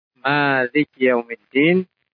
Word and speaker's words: Maliki yaumiddin: Adapun secara bacaan, mimnya bisa dipanjangkan, bisa pendek. Maliki yaumiddin, bisa pula maliki yaumiddin. Kalau panjang, malik yang menguasai. Kalau pendek, malik. Malik itu raja.